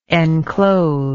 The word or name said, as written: enclothe